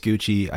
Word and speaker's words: Gucci: Last Duel is probably most undoubtedly better than House of Gucky. – Gucky. But for... – It's actually pronounced Gucci. I know.